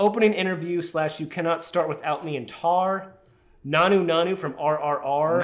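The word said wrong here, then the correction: Naatu Naatu: Natu Natu from RRR.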